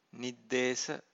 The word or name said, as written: niddesa